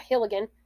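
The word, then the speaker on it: Helgelien: So, in December of 1907, Andrew, how do you say his name? – Hilligan? – Yeah, Hilligan.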